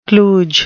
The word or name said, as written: kludge